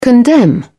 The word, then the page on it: condemn: British English